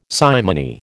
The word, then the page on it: simony: American English